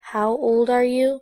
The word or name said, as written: how old are you?